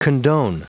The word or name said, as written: condone